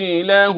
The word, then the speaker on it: له: خلي بالك، له رزق، له أو الصلة، الصلة الصغرى. يا قارئ القرآن، هو له تتنطق ازاي؟ – له. – مرة ثانية له. – له هكذا بالمعروف.